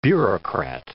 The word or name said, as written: bureaucrat